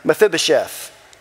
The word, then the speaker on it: Mephibosheth: David is said to have shown chesed to his friend Jonathan's crippled son, Mephibosheth.